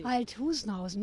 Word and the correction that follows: Althusmann: Wie wird er denn nun ausgesprochen? – Nein, Althusmann, ne?